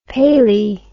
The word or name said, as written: palae